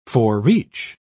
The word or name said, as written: forereach